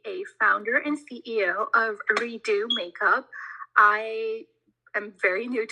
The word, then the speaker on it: Redux: It's called Redo Makeup, and you would spell it as R-E-D-U-X Makeup.